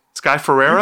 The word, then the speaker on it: Ferreira: Sky Ferreira? – You got it.